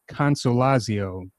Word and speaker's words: Consolazio: The case that we're going to be talking about is called Lowe versus Southern California Gas Company and Dominick Consolazio. Did I pronounce that right? – You did.